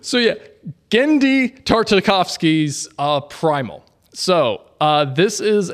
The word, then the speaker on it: Genndy: I was struggling on how to say it, but I'm glad you said Genndy. – It. Well, how do you say it? – Genndy. – Oh, it's Genndy.